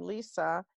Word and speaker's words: Lisè: The thing is that, like my red lipstick, one of the things that distinguishes me is that my first name, Lisa, is spelled L-I-S-E, mm-hmm. instead of the traditional Lisa with an A.